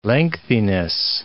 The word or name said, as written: lengthiness